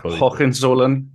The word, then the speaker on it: Hohenzollern: He was awarded the House Order of Hohenzollern. – Hohenzollern. Yeah. – Okay. – Yeah, you're doing fine.